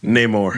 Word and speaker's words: Namor: So this is a much more standard, normal publicity cycle start, and also we've already gotten leaks of Nomar? How do you pronounce it? – Namor.